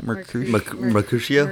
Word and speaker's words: Mercutio: You have, you know, the whole Tybalt and Romeo thing, and then Mercutio. Am I saying that right? – Mercutio.